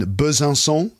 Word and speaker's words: Besançon: but you live out in Besançon. Check out my pronunciation. I can actually say it correctly now. – Not bad. Well done.